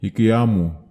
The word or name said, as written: ikiamu